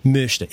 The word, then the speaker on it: möchte: Well, let's say you want to say möchte. Ich möchte. You make an O, you say an O, but you make your mouth in the shape of an E while you're pronouncing the O.